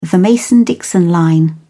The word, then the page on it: mason-dixon line: British English